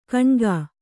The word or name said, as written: ಕುನಿಕೆ